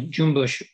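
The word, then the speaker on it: cümbüş: And in this one, the instrument, I believe, is jumbush, which is pronounced like C-U-M-B-U-S with all kinds of artifacts on half the letters, and a jumbush oud.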